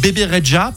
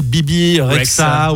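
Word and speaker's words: Bebe Rexha: Et j'ai pris le parti, il y a quelques années, de l'appeler et de donner son nom à l'antenne comme Bébé Reja,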